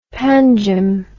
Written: panjim

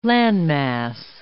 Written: landmass

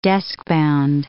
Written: deskbound

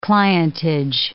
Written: clientage

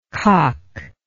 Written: cocklike

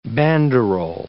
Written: banderole

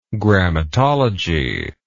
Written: grammatology

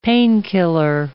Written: painkiller

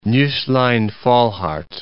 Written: Nusslein-Volhard

